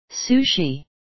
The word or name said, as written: sushi